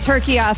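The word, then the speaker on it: Türkiye: I will be saying it turkiya from now on. – And my entire lifetime, we have been incorrectly pronouncing turkey.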